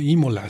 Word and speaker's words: Imola: Una banda italiana que se llama Zeus, y es de Imola, así se pronuncia.